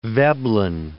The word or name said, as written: Veblen